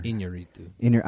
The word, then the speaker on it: Iñárritu: I've never Iñárritu.